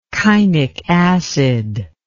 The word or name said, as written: kainic acid